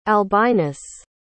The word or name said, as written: albinus